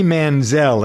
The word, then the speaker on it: Manziel: All right, speaking of thinking and not thinking, Johnny Manziel, that's how you pronounce his name, right? – Yes.